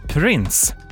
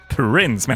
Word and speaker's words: Prince: Nu, Prince. Prince menar jag, lite sånt fräckt engelskt uttal.